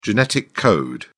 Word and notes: genetic code: British English

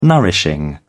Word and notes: nourishing: British English

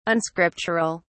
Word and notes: unscriptural: British English

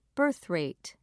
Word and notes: birthrate: British English

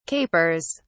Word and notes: capers: British English

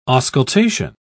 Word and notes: auscultation: British English

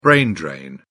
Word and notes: brain drain: British English

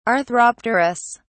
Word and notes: arthropterous: British English

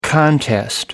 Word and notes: contest: American English

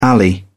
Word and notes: alley: British English

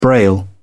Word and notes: braille: British English

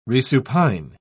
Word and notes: resupine: British English

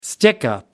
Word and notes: stickup: British English

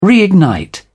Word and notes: reignite: British English

